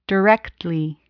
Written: directly

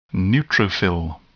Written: neutrophil

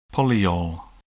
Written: polyol

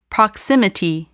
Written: proximity

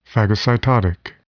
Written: phagocytotic

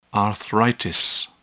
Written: arthritis

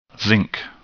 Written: zinc

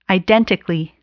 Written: identically

